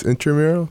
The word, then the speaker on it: intramural: I always want to say intramural, but it's technically intramural. – Oh, it's intramural? – Yeah, it's spelled with an R-A, intramural.